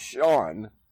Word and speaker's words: Sean: My middle name is Sean, but nobody knows how to pronounce it because it's S-E-A-N.